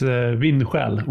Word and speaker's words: Vindsjäl: It's in Swedish, it's Vindsjäl.